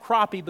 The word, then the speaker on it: crappie: It's pronounced crappie because it ends with an I-E and not a Y.